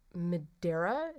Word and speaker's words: Madeira: According to the bill, the founding fathers drank 54 bottles of Madeira. Is that right? Am I pronouncing that right? M-A-D-E-I-R-A? – Yeah, sounds right.